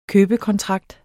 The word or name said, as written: købekontrakt